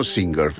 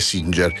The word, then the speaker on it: Singer: Si chiamano Singer, erano proprio delle macchine da cucire e la pronuncia corretta era quella, però insomma tutte le abbiamo sempre chiamate Singer e così hanno continuato.